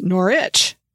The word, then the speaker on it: Norwich: For example, Norwich is pronounced Norwich, like Nor and Itch, mm-hmm. like you scratch.